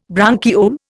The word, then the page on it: bronchiole: Indian